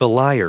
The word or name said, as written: belier